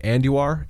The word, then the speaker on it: Andujar: Gleyber Torres, Miguel Andujar. Is that how you say it? – Andujar.